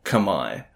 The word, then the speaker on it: Khmer: known as the Khmer, or Khmai, to pronounce it more accurately, living in and around the region that we now call Cambodia many thousands of years ago.